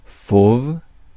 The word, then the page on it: beast: /biːst/